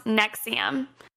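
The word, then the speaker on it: NXIVM: It's pronounced Nexium.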